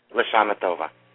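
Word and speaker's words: L'Shanah Tovah: How do you say it again? – Lashana Tova.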